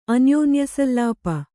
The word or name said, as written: ಅನ್ಯೋನ್ಯಸಲ್ಲಾಪ